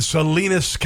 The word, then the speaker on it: Salinas: Let's go to Don in Salinas, California. Rhymes with weenus.